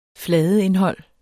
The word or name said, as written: FLADEINDHOLDS